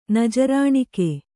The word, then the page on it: ನಜರಾಣಿಕೆ: Kannada